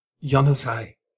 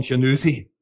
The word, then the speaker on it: Januzaj: And help me with the pronunciation of this in Belgium, because Januzaj. – In Brazil here, they call him Januzi.